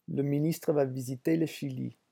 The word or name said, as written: le ministre